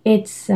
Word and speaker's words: it's: The problem is he doesn't pronounce the T in it's a software program, okay? It's.